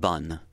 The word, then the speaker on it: bonne: The masculine is the word B-O-N, bon, and then the feminine has a double N and an E, B-O-N-N-E. And so the difference is bon and bun, bon and bun.